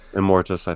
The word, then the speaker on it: Immortus: Immortius, mm-hmm. – if I'm saying that right. It's Immortus or Immortius? – Immortus, I think.